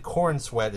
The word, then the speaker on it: Corenswet: Corianis. – David Corenswet is, I believe how you pronounce his name, as the titular character of Superman, Clark Kent.